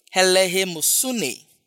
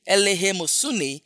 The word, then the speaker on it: eleemosune: The phrase charitable deeds is from one Greek word, helehemosune. That's the Greek word, charitable deed. Helehemosune is spelled E-L-E-E-M-O-S-U-N-E.